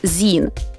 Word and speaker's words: zine: Він написав: Zine pronounced as zine, short for magazine. A lot of people pronounce it wrong.